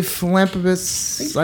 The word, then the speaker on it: philanthropist: I just don't feel like he was the billionaire Playboy philanthropist. I said that wrong. – Please say that again. – Thank you. – Philanthropist. Say it with me now.